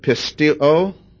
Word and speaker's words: pisteuo: Believe is a Greek word pisteo. Spell it in English, yes, P-I-S-T-E-U-O. And then the form of the noun is pistis, P-I-S-T-I-S.